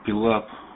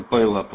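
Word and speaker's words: pile-up: А, вспомнил, вы с кем-то говорили, пилап. Ну, произносится пайлап вообще-то правильно.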